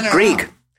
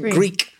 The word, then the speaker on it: Grieg: Not Grieg. Grieg. Composer Grieg. – Oh, he's a Scandinavian composer. – I see. – Say his name again. – Grieg. – Grieg. Oh, Grieg. Grieg. I thought you said Greek.